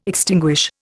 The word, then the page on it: extinguish: Indian